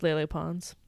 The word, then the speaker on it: Lele Pons: Is this Lily? Or I don't know how you say her name. – It's Lele Pons. – It's Lele Pons. I think it's Lily. – You're probably right.